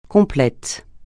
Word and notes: complet: francais

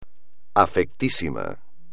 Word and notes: afectísimo: espagnol

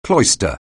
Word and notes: cloister: anglais

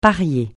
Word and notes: parier: francais

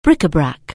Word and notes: bric-à-brac: anglais

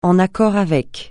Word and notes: en accord avec: francais

